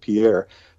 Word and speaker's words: Pierre: What people don't realize that from Pierre, South Dakota, you would pronounce it Pierre, South Dakota,